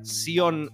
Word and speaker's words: Zion: Zion se pronuncia Sion, se escribe como una película de Matrix,